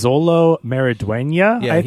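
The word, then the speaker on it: Xolo Maridueña: His name is Zolo Maridueña, is how you pronounce it.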